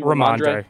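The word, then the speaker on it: Rhamondre: How do you pronounce his first name? – Ramondre. Yeah.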